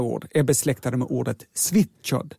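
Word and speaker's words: Svíþjóð: Så här uttalas det. – Svitjod. – Svitjod.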